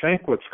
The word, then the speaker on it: Shankwitz: Yeah, common spelling, Shankwitz. Common spelling, as we say in the police world. – Okay. – It, yeah. – It's spelled S H A N K W I T Z.